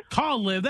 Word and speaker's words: Caleb: C-A-L-E-B. – How do people pronounce that? – Caleb. – Caleb.